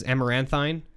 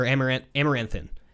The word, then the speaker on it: Amaranthine: I was like, how do you say this? You're like, it's a very weird word, but I think it's a short I like the word thin. So Amaranthin.